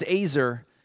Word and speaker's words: ezer: It's these letters, E-Z-E-R, pronounced Azer.